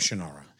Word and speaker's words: Shannara: Shannara.